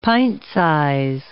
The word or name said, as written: pint-size